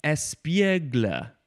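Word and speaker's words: espiègle: I think it is pronounced espiegle. Espiegle. And it is spelled E-S-P-I-E-G-L-E. There is an accent on the E in the middle that is up on the left and down on the right.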